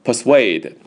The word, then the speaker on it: persuade: That person personally persuaded, 把 D 去掉， persuade, 注意啊，重音在 SUA 这里啊， persuade, 不要念成 persuade, 是 persuade, 表示说服啊，一般他的用法就是 persuade somebody to do something 啊，你很简单，你说，哎，你去试一试啊，他不肯和我们一起去玩呢，你试试啊，你说服他和我们一起出去玩呢。